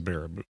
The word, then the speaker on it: Baraboo: But it could trip you up if you haven't heard the name pronounced before. So it's Baraboo. Yeah. And this is actually, yeah, it's just a little ways from here. So say it again. – Baraboo, Wisconsin. – Yes, it is Baraboo.